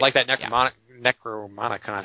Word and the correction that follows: Necronomicon: How do you say that? Necronomicon. – It's a it's the Necronomicon ah.